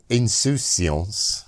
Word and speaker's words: insouciance: And one tale that appeals to us is the simple insouciance.